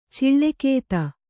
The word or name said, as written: ಚಿಳ್ಳೆಕೇತ